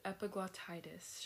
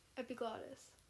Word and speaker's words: epiglottitis: Let's start with epiglottitis, shall we? – Yeah. – Okay. So this is... – How do you say it? – Epiglottitis. – Epiglottitis. – Mm-hmm. – Epiglottis. – No.